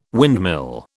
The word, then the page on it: windmill: British English